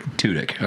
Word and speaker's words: Tudyk: and the K-2SO robot, Alan Tudyk. Is that how you say his name? – Alan Tudyk. – Tudyk. Okay. – He got two dicks.